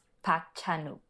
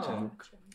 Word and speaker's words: Park Chan-wook: I can say that it's pronounced Park Chan-wook. – Oh. Park Chan-wook.